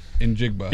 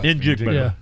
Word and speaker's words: Njigba: Njigba? – Njigba. – Njigba.